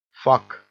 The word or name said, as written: fuck